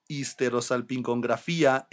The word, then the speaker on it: histerosalpingografía: Angel used the Spanish word that more closely resembles histerosalpingography. Histerosalpingografía. La histerosalpingografía.